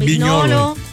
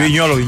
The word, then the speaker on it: Vignolo: Se escribe Vignolo, pero es Vignolo. – Ajá. Miguel Simón, Vignolo. – Mariano Clos y Fernando Palomo. – Bacano. – Es Vignolo.